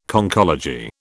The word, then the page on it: Conchology: British English